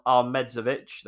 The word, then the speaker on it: Ahmedhodzic: Uh, Anel Ahmedhodzic, that's how it's pronounced. Ahmed Hodzic is, I was told, is exactly how we should be pronouncing it.